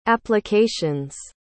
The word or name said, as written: applications